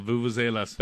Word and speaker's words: vuvuzela: I didn't even know what a vuvuzela was. Did I say it right? Vuvuzela? – Yeah, vuvuzelas.